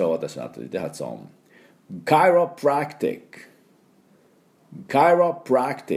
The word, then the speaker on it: chiropractic: で、第二、アクセントですから、そんなに強く、カイロプラクティックっていうふうに言いませんので、カイロの方が、アクセントがありますね。このカイのところですね。で、カイロプラクティック。ちょっと軽めに。